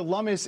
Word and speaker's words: Lummis: Senator Cynthia Lummis is co-sponsoring the bill, and she agrees nobody is popping the champagne quite yet.